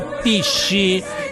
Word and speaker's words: PSSI: Partito super sovranista italiano PSSI si pronuncia PISSI l'unico partito che ti difende ogni giorno di più.